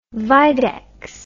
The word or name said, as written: videx